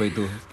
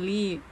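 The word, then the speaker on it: I (이): Ternyata setelah gue datang ke Korea, men, bacanya bukan Li, cuy. – Bacanya I. – Tapi itu kan sesama orang Korea aja sih, I.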